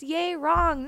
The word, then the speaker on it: Yerang: But then they were really also supportive at the same time because they'd laugh, but then they'd be like, no, teacher, it's Yerang, not Yerang.